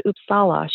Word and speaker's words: Uppsala: I always say it wrong, but it's the University of Uppsala. Not Uppsala, but Uppsala.